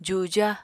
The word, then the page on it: juja: apatani